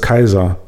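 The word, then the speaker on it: Caesar: Aber äh, das fing dann so an, dass er sagte, äh, das wird nicht Cäsar ausgesprochen. Ne? Weil C wird wie K ausgesprochen und AE wird nicht wie Ä, sondern wie Ei. Also heißt das Kaiser und nicht Cäsar.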